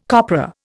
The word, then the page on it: copra: Indian